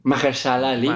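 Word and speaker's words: Mahershala Ali: Maharsha Ali. Maharshala Ali. Maharshala. Me faltó, una sílaba.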